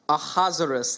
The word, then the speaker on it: Ahasuerus: There's some debate, but a lot of people believe that uh, Ahasuerus, that's how you say his name.